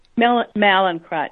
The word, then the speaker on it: Mallinckrodt: Is it the Mallinckrodt? Is that how you say it? Mallinckrodt? – Mallinckrodt, yes.